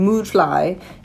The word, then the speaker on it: Mudfly: So So then we flash to Lord Badgley, and he's talking to Moodfly, and he wants him to follow. – Why do you say it like that? Moodfly. – Because it's spelled mudfly, but they keep saying Moodfly because of the umlaut.